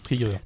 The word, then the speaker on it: Trigger: Trigger.